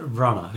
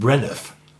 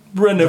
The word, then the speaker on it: Branagh: Naja, da ist doch ein GH am Ende des Namens. – Ja, das wird Branagh. – Das ist stumm.